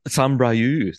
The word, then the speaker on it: Saint-Brieuc: So Saint-Brieuc, there you are.